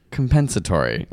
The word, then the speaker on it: compensatory: How do you say that word? Compensatory. – Compensatory. I don't know. Compensatory. – Compensatory, like compensation.